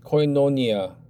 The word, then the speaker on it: koinonia: And the Hellenic pronunciation is koinonia. Koinonia.